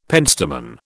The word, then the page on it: pentstemon: British English